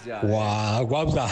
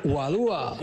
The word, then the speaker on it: guadúa: Guagua. Se dice guadúa.